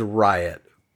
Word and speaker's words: Rhyatt: Kids named Riot, but it's spelled R-H-Y-A-T-T. Riot. – R-H-Y-A-T-T.